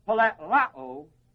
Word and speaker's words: pleroo: The other word, also the same basic root, same basic thing, is the word pleroo, P-L-E, long E-R-O-O, long O. Now that word, plerao, is simply filled like a river is filled in its natural or normal flow.